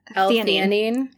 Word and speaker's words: theanine: I've been taking – I'm going to say this wrong. This is like the hyaluronic acid for you, but it's for me. Theanine? – Yes. – L-theanine. L-theanine.